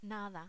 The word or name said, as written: nada